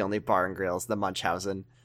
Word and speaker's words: Munchausen: You just YouTube pronunciation book Munchausen, and it'll tell you that it's pronounced Munchausen.